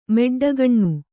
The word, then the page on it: ಮೆಡ್ಡಗಣ್ಣ: meḍḍagaṇṇa